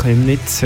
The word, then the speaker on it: Chemnitz: Chemnitz, ja.